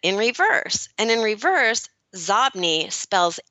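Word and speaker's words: Xobni: It's Zobny. And believe it or not, it was originally pronounced Zobny, but Bill Gates pronounced it Zobny. So the company changed the pronunciation of its own name because Bill Gates pronounced it Zobny. Okay, so now we know it's pronounced Zobny.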